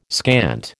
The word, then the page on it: scant: American English